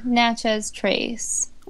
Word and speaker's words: Natchez Trace: Natchez Trace.